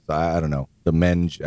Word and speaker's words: Menge: It's in Past Christiane, but it's on the, what is it, the Menge? – Menge. – M-E-N-G-E. I think it's pronounced Menge.